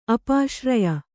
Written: ಅಪಾಶ್ರಯ

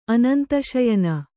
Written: ಅನಂತಶಯನ

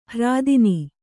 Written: ಹೆರತನ

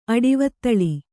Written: ಅಡಿವತ್ತಳಿ

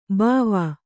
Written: ಭವ

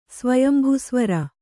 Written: ಸ್ವಯಂಭುಸ್ವರ

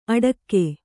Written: ಅಡಕ್ಕೆ

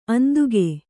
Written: ಅಂದುಗೆ

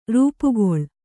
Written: ರೂಪುಗೊಳ್ಳು

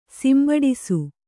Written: ಸಿಂಬಡಿಸು

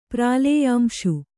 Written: ಪ್ರಾಲೇಯಾಂಶು

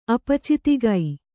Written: ಅಪಚಿತಿಗೈ

